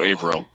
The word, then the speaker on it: Averill: It's like somewhere between those two points, so Averill.